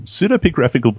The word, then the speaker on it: Pseudepigraphical: Pseudepigraphical book. Am I pronouncing that correctly? – Yeah, usually you're getting it, yes.